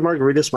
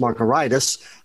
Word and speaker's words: Margaritis: My last name ends in an IS, so it should be pronounced Margaritis. But that sounds too much like gingivitis, hepatitis, and laryngitis. So we pronounce it like a cocktail because I want everybody to feel comfortable when they're around me. Yes, I am not a very good accountant.